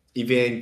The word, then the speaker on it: Evangelion: I've only seen one episode of Evangelion.